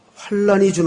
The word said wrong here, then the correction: 환난: 제가 지금 발음을 사실은 잘못했습니다. 성경에는 환난이라고 읽어야 맞습니다. 환란과 환난이 틀리더라고요. 뒤에 란자가 붙으면 앞에 환란이라고 읽는 게 맞아요. 그런데 뒤에 난자가 붙으면 국어학적인 발음상 환난이라고 읽는 게 맞습니다.